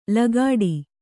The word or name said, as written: ಲಗಾಟಿ